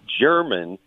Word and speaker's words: Jermyn: I think just north east of Scranton is where German Pennsylvania is, and it's like J-E-R-M-Y-N. It's, you know, a totally different spelling, but it looks like it's pronounced German Pennsylvania.